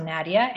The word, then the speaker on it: Nadia: I am on Facebook, I'm on Twitter, I'm on Instagram, and it's, I think, Nadia Pataguana throughout. – You should spell that for us. We're going to put a link too, but spell it for us anyway. – Okay. So Nadia, N-A-D-I-A, and last name Pataguana, P-A-T-E-G-U-A-N-A, P-A-T-E-G-U-A-N-A, Pataguana.